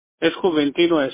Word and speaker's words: Juventino: My real name is Juventino Estrada.